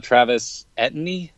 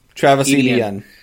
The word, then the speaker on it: Etienne: Travis Etienne. – Travis Etienne.